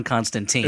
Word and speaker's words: Constantine: He says, in a recent episode, Elliot pronounced John Constantine's name as Constantine instead of the actual correct pronunciation. See, it's not only Dan who gets words wrong. PPS, but it's still mostly Dan. – Look, as long as I'm Jewish, I'm going to pronounce it John Constantine. – It's properly pronounced Constantine.